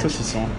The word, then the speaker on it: saucisson: Is it saucisson? – Yes. – Is that right. – How you pronounce it? – Saucisson.